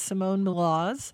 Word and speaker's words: Simone Maloz: And today that person is Simone Milaz.